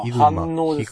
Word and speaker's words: 飯能: あ、一部商品のみ取り扱い店ですが、埼玉もね、川越市、い、飯能って読むんですかああ、反応ですね。反応。